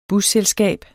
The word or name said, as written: busselskab